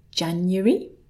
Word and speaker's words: January: So here we have the first one, and I personally pronounce this as January. January. Three syllables and a kind of y with a schwa sound in the middle.